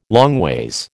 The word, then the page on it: longways: American English